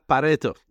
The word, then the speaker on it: Pareto: Was that the correct pronunciation? – Yeah, Pareto.